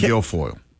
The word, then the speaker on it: Guilfoyle: Kimberly Guilfoyle.